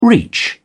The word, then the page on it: reach: British English